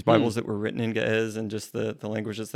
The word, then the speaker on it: Ge'ez: So it's like G-E hyphen or apostrophe E-Z. And mm-hmm. it's funny because if you look up online how to pronounce G-E-E-Z, then it's G's. But yeah, Ge'ez.